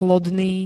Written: lodný

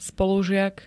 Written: spolužiak

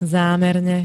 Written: zámerne

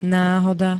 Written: náhoda

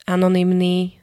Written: anonymný

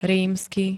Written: rímsky

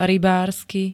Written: RYBÁRSKY

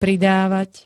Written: pridavat

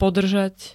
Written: podrzat

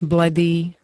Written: bledý

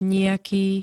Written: nijaký

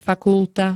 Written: fakulta

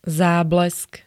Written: záblesk

